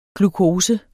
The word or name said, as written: glukose